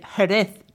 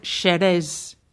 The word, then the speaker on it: Jerez: Well, we say Jerez today, but in past times the J was pronounced sh, like S-H, sh. So the name of the town was Jerez. Unlike today, where J in Spanish is pronounced more like an aspirated H.